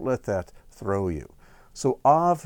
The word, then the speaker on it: Av: Now, this letter here, this second letter, Beit, can be pronounced with a V sound or with a B sound.